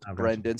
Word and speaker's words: Brenden: Yeah, you probably just put it in the show notes, but my name is spelled Brenden, so that's Brenden, and you'll be able to find the LinkedIn page on my YouTube channel.